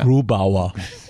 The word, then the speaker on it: Grubauer: Grubauer. You have to say it like that, I'm sorry.